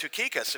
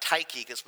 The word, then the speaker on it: Tychicus: Uh, that's actually pronounced Tukikus, if you can believe that, not Tychicus, but tukikus And Trophimus these guys,